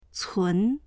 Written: cun2

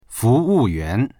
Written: fu2wu4yuan2

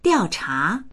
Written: diao4cha2

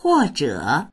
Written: huo4zhe3

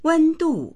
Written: wen1du4

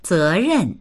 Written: ze2ren4

